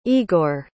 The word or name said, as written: Igor